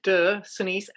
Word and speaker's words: de Sonis: I'm going to spell that D-E de Senis, S-O-N-I-S, which isn't exactly proper French, but we decided to do that so that people would understand it when when they're listening to it.